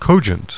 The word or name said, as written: COGENT